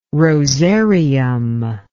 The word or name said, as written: rosarium